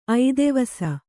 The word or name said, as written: ಐದೆವಸ